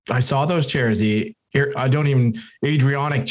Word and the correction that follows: Adirondack: chairs. Is that how you pronounce it? Yeah, they were very nice. – Adirondack. – Adirondack. Yeah. Wow. – That's right.